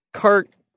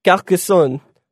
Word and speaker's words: Carcassonne: It's a French town, so I'll attempt to say it with the French accent. Carcassonne. That's how you'd probably say it, Carcassonne.